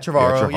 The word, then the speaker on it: Trevorrow: am I saying his name right? – Yeah, Trevorrow. Yeah.